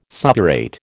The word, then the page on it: suppurate: American English